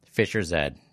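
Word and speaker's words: Fischer-Z: They just wanted it to sound – look that way and have it pronounced Fisher Zed.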